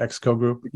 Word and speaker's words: XCO Group: XCO Group? – Yeah, good.